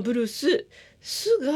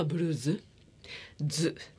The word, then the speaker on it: ブルーズ: あの本を読んだ時にそういうの書いてあったと思うんですが、でこのちなみに、ブルースなんですけども、まあ、ブルーススが一般的に耳慣れているかとは思いますが、正式の発音はブルーズズで、